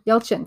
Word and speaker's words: Yelchin: Yelchin, thank you.